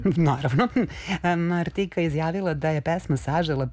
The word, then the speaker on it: Martika: Vidiš ko je Martika ili Martika sad. I tu je pitanje kako pravilno da izgovorimo. Gledala sam intervjue, ona samo sebe zove Martika, ok.